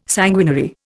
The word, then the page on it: sanguinary: Indian